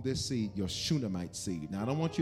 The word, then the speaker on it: Shunammite: But for those of you who do want to spell it, it's S-H-U-N-A-M-M-I-T-E. Shunammite. Shunammite.